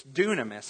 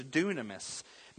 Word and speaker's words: dunamis: It's dunamis, spelled like dynamis, but it's dunamis, meaning power and strength and ability.